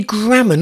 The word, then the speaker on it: Grammer: It's Andy Grammer, not Andy Graham. I said it wrong.